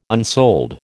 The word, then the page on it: unsold: American English